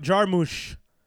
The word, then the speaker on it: Jarmusch: Mobs wrote Jarmusch. That's how you pronounce it.